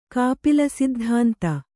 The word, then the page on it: ಕಾಪಿಲಸಿದ್ಧಾಂತ: Kannada; kāpila siddhānta